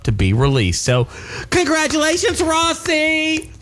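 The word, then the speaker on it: Rhossi: I should have probably shortened that out to Rossi and not made Rossi.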